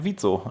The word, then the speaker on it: WIZO: Den hätte ich gerne, dabei überlege ich mir die ganze Zeit, heißt diese Band, wird Vizo ausgesprochen oder Wieso? Warum gibt es Menschen, die Wieso sagen? W-I-Z-O ist doch Vizo, oder? – Ja, eigentlich schon, aber es gibt ganz viele, die sagen Wieso.